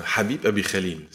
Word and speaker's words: Habib Abi Khalil: Donc, moi, c'est Habib Abi Khalil. C'est comme ça que ça se prononce.